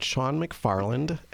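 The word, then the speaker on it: Shaun McFarland: I am at Sean McFarland, and that's spelled S-H-A-U-N, my last name, MCFARLAND.photography.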